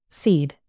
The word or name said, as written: cede